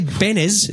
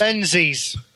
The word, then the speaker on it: Benzies: Leslie Benes. Is that how you pronounce his last name? Benes? Benzies? – Benzies.